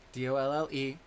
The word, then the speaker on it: Dolle: Dolle. – Yes, Katydolle. The E is silent, guys, and it's not a stage name.